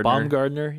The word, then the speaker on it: Baumgartner: Baumgartner. – Baumgartner.